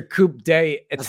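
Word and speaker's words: coup d'état: You just butchered the pronunciation. That's what I'm saying. That's your coupe day etat.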